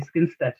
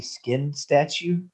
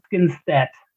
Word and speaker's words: Skinstad: One of the presenters was a guy called Bobby Skinstad, who was the Springbok of the, did you say his name was Bobby Skin Statue? – Skinstad.